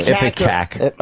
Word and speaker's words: ipecac: How do you say that word? I-P-E-C-A-C? I can never say that word. – Ipecac. – All right, Ipecac.